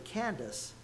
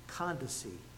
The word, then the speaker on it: Candace: Now, I pronounced it Candace, but that's not the way it's pronounced. The actual thing is he was saved in the court of Queen Candace, is the actual way it's pronounced.